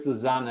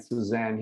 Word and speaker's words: Susanne: And it's our exotic escapism expert, Susanne, how she is pronounced in Germany, and Suzanne here.